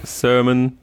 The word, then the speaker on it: Sermon: Und Eric Sermon. – Sermon, ja, die Betonung.